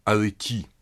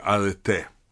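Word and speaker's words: «арете» (ἀρετή): Это слово «арети» или «арете». Ударение на последний слог.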